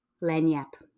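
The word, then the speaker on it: Lagniappe: How do I say it again? Louisiana. What did it say? Lenyap. That sounds so much easier than what the word actually looks like.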